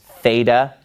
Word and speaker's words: theta: In math, you called this theta using an American pronunciation. But this letter in Greek is the letter theta, and it makes a th sound.